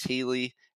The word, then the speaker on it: Haley: So I go into our, how do you pronounce my name channel and say, hey, it's Alex Haley. And I did like the phonetic spelling. I did like HEE-LEE.